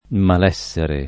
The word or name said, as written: malessere